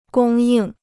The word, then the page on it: 供应: gōng yìng